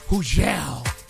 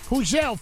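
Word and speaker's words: Hugel: Hujel. The G is silent. Hujel, Funk Flex, Bobby Trends.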